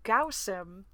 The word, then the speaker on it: Guosim: while I was reading on the call, Ben called me and Kit out for pronouncing Guosim wrong. He says that it's supposed to be pronounced Gausim, because in one of the books they rhymed the acronym with the word douse him.